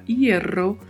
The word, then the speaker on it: hierro: Hierro, a primeira letra da palavra hierro, é a letra H. A letra H não é pronunciada. A letra E tem sempre um som fechado, então utilizamos a sonoridade E. E o duplo R tem um som vibrato. Então falamos hierro. Hierro.